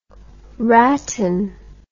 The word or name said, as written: ratten